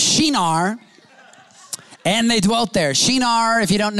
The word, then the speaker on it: Shinar: You should know that Shinar, whether I'm pronouncing that wrong or right, Chad, is that right pronunciation of Shinar? Yeah. It's Shinar.